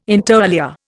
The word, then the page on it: inter alia: Indian